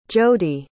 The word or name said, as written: jodie